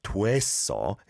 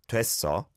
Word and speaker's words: 됐어: Yeah, you can see on the 됐어, it's an o e vowel sound, so where, but it's not overly pronounced like 됐어. It's just 됐어, 됐어, 해결됐어. Good stuff.